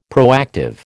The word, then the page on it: proactive: American English